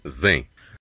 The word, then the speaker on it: Xen: O primeiro sistema operacional moderno que implementou essas modificações foi o Linux, com um projeto conhecido por Xen, ou na pronúncia inglesa, Zen.